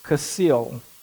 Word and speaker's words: Kasil: But it's just three, K-N-E. The first one starts with the K. It was what? Yes, Kasil. Yes, but that's good. Kasil is the correct pronunciation.